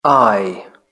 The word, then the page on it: eye: British English